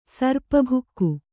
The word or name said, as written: ಸರ್ಪಭುಕ್ಕು